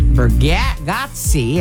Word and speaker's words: Bargatze: Also, let's talk a little bit about Mandy Moore, of course, from This Is Us, and Nate Bargatze.